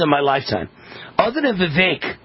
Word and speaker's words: Vivek: And that's how he says it's pronounced. Vivek rhymes with cake.